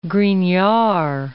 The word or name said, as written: Grignard